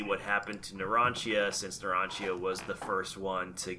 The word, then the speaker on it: Narancia: It's hard to tell where, like, the proper pronunciation ends and, like, the Japanese accent begins, but I think it's Narancia. – No, yeah. – It is because... – In Italian pronunciation, the C is often pronounced with a ch.